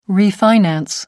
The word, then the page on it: refinance: American English